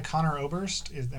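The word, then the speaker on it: Conor Oberst: I know the guy Connor Oberst, am I saying his name right? – Yeah.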